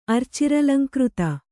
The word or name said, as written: ಅರ್ಚಿರಲಂಕೃತ